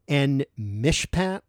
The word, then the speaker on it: En Mishpat: On the map, this looks like it should be pronounced something like En Mishpat, E-N-M-I-S-H-P-A-T. Well, this is later known as Kadesh.